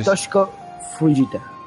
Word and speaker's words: Toshiko Fujita: se eh, pronuncia Toshiko Fujita.